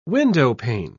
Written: windowpane

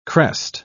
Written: crest

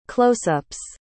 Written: closeups

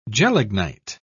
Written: gelignite

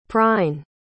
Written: prine

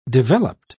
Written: developed

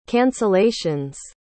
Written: cancellation's

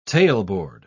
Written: tailboard

